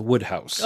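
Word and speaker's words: Wodehouse: Small nit to pick, his name is pronounced Woodhouse.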